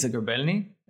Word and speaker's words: Zagrebelny: So you pronounce it correctly. It's Zagrebelny. It's Ukrainian in origin. A little bit hard to pronounce for some people, but you done great.